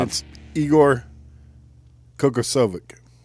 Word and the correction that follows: Kokoskov: There's not an ick at the end. – Spell it one more time. – K-O-K-O.